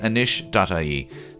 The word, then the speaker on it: anois: They are systems designers at anish.ie. That's the Irish for now. Anish is spelled A-N-O-I-S.